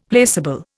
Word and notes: placable: Indian

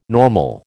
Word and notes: normal: American English

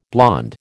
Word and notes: blond: American English

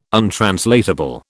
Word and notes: untranslatable: British English

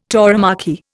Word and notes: tauromachy: Indian

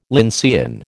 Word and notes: lyncean: American English

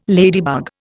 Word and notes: ladybug: Indian

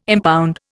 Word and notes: impound: Indian

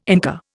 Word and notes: Inca: Indian

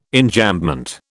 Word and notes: Enjambment: British English

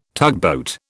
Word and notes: tugboat: British English

